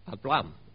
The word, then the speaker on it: Abraham: 那个名字如果用那个音来发出的时候，就大概是这样发音，就是 Abraham。